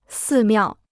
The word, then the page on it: 寺庙: sì miào